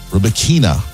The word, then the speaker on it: Rybakina: Coco Gauff onto the semifinals, where she will face second seed Elena Rybakina.